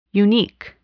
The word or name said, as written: unique